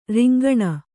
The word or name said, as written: ರಿಂಗಣ